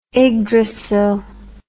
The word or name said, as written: igdrasil